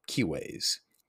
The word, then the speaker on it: cives: Kives. Kives, what declension is it? Well, it's spelled C-I-V-E-S.